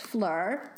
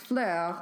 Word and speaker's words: Phlur: The first one I'm going to talk about is Fleur, P-H-L-U-R, but it's pronounced Fleur, like the French word for flower, which is something I can almost pronounce better than Italian, but not really by much.